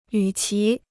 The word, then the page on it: 与其: yǔ qí